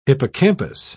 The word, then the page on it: hippocampus: British English